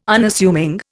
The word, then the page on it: Unassuming: Indian